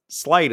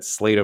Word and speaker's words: Sleight: It's Sleight is the correct pronunciation, yeah? – Correct. – So it was Sleight of Mind. It was the card from my childhood, and I always said Slate of Mind, and my brothers would endlessly bag on me about calling it Slate of Mind.